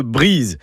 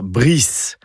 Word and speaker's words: Brize: Alors j'insiste bien sur la prononciation de Brize. D'ailleurs, écrit avec un Z, ça éviterait peut-être d'entendre dire Brice.